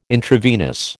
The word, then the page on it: intravenous: American English